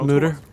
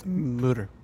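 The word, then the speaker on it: Mütter: It's pronounced Mutter. – Absolutely. – Mutter. – Mutter Museum. I apologize.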